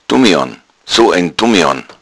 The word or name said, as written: dummian